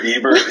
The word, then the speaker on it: Ebert: And apparently there's a movie reviewer named Roger Ebert. I hope I'm saying that right. And apparently... – Hold on a second. You don't know who Roger Ebert is? – Ebert.